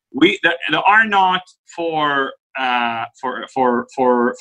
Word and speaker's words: R naught: It's called R0 or R naught. It's pronounced R naught.